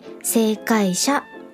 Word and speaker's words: 星海社: 2015 年正解者新書から出版されています。正解者新書の正解という漢字は星空の星に日本海とか太平洋の海という字を書いて正解者新書と読みます。